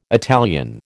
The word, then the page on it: Italian: American English